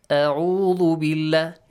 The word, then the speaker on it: أعوذ: First one is همزة, second one is عين. أعوذ بالله.